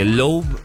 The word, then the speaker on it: Loab: Tengo las imágenes acá, así que si ustedes quieren, escríbanme fotos, porque Loeb se escribe L-O-A-B,